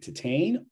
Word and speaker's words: Titane: Okay, so before I answer the question, I, I want I want us to be correct in this that the proper pronunciation for uh, Julia Ducournau's film is Titan.